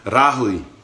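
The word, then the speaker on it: rāhui: So today's word is Rahui, R long A H I U I. Sorry, do that again. R long A H U I. Rahui.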